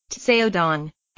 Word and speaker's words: Caodong: Two special Chan meditation methods, derived from the Caodong, pronounced Tseodong,